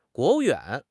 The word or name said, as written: 国务院